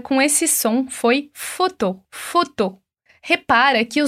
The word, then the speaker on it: photo: Então, se no português a gente diz foto, o primeiro com som de O e o segundo com som de O, no francês, a gente só vai ter o som de O. Então, repete comigo. Photo.